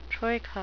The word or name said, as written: troika